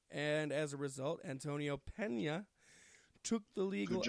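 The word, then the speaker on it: Peña: Say his name right. It's Pena. – Pena, like a colada. It's spelt the same.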